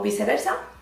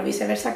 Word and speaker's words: viceversa: Tenemos como que medio separarla, así como ellos, que lo ponen separado y es vice, vice, versa. Con la ¿sabéis? Esta así que ponéis la cara de esos hijos. Viceversa. Viceversa.